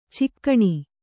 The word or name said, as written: ಚುಕ್ಕಣ